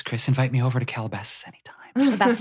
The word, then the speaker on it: Calabasas: Say it right. Calabasas. – Calabasas.